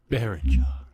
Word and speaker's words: Berninger: And if you're a fan of The National, the band, Matt Berninger, can't say it. Do you know how to say it? He's going to go on air. Come on, tell me how to say it. – Berninger.